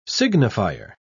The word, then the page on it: signifier: British English